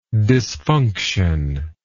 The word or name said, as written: disfunctional